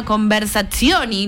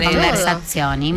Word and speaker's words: Conversazioni: La escultura de la que vamos a hablar es una escultura en bronce, uh-huh, que se llama Conversazioni. No sé si lo dije bien. – Perfecto. – Perfecto, mirá. Menos mal. No, sí, porque acá... – ¿Qué este idioma? Conversazioni, muy bien.